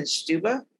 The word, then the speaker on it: stupa: stupa.